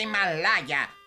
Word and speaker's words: Himalaya: Según esto, estamos en alguna parte de los montes Himalayo. – ¡Se pronuncia Himalaya! ¡Himalaya! ¡Ay, eres un tonto de capirote! ¡Estamos en Asia, Himalaya!